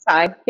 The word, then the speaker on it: sai: Sai.